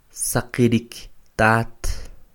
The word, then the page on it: Saqirik tat!: Quiché